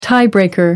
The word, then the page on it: tie breaker: American English